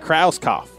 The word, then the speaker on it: Krauskopf: Krauskopf.